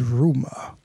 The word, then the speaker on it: RMR: Name is Rumor. – And it's spelled R-M-R. – Rumor. – Rumor. No vowels.